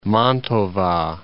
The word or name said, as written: Mantova